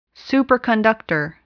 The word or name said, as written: superconductor